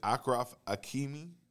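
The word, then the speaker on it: Achraf Hakimi: Is it Akrof Hakimi? – Akrof Hakimi. – For the record, yeah. Yes, it is.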